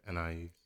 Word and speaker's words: Anaiis: How do you say your name? – I said Anais.